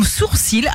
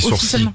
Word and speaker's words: sourcil: Et puis, il y a des mots sur lesquels on se plante toujours dans leur prononciation, comme sourcil. Alors ça, je savais pas. Le L est officiel. – On dit sourcil, ouais.